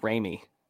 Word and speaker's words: Remy: And to set the record straight, it's Remy. – Remy. – Not Remy. You've all been pronouncing it wrong this whole time. – Shame on you. It's Remy technically. It's a long backstory there. But yeah, the upshot is it's Remy.